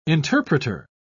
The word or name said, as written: interpreter